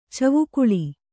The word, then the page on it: ಚಿವುಕಳಿ: civukaḷi